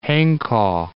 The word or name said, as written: Hanko